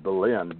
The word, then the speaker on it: Belen: Is that how do you say the name where you have your little ponies? Because there's a history with that too. – Yes, it certainly is. The little town called Belen, B-E-L-E-N,